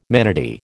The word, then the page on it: manatee: American English